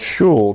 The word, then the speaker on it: shul: Orthodox Jews often use the Yiddish word shul, pronounced shul, to refer to their synagogue.